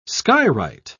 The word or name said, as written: skywrite